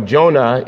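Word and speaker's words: yonah: Jonah is how you say dove in Hebrew, and the Holy Spirit is representative of the dove.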